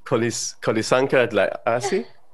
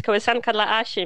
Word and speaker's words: Kołysanka dla Asi: And he did a little lullaby, which I don't know if I'm pronouncing it correctly, as Kolisanka Dlasi. – Well, that's actually pretty good. Yeah, that's exactly what it means. Kołysanka dla Asi means lullaby.